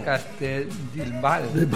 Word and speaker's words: Castellbisbal: a Castellbisbal. Castellbisbal. No sé si lo he dicho bien, pero ¿te suena? – Castellbisbal. – Ha parecido.